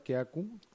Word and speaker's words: Kiaku: Así es muy chistoso porque, bueno, yo aprendí la palabra Kiaku, se pronuncia Kiaku, que es Wixárika, es el saludo Wixárika.